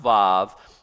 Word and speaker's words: Vav: It sounds like V, it's pronounced Vav. It's like V as in vine, it's number six.